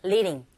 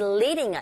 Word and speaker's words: leading: leading이라기보다는 모음과 모음 사이의 드 사운드도 트 사운드와 똑같이 R 소리에 가깝게 들리게 되죠. 그래서 leading이라기보다는 leading, leading. 이때 reading이라고 하시면 안 되죠. leading이라고 해서 L 사운드니까 혀끝이 윗니 뒤로 붙여서 발음이 되면 돼요.